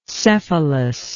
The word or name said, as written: cephalous